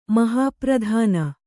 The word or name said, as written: ಮಹಾಪ್ರಧಾನಿ